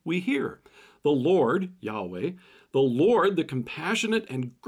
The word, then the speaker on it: Yahweh: In Hebrew, God's name is pronounced Yahweh, and out of reverence for God's holy name, the word Lord was often spoken in its place.